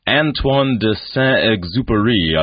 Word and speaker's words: Antoine de Saint-Exupéry: This is by an author by the name of Antoine de Saint-Exupéry. I'm not sure how my French pronunciation... – Excellent.